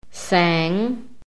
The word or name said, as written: SANG